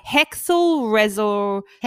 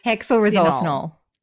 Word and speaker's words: hexylresorcinol: Hexel resol. – hexylresorcinol.